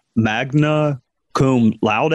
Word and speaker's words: magna cum laude: Magna cum laude.